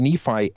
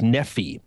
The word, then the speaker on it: Nephi: Now, we as 21st century Americans and, of course, 20th century Mormons have pronounced this as Nephi, but sometimes the I on the end of Nephi can be pronounced as an E, as a long E.